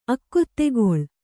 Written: ಅಕ್ಕೊತ್ತೆಗೊಳ್

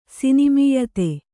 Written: ಸಿನಿಮೀಯತೆ

